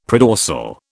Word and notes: predorsal: British English

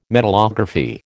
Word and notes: metallography: American English

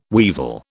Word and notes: weevil: British English

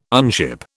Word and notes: unship: British English

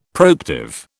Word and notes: proactive: British English

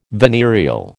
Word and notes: venereal: American English

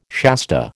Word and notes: Shasta: American English

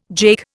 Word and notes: jake: Indian

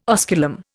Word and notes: osculum: Indian

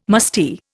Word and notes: musty: Indian

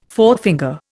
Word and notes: forefinger: Indian

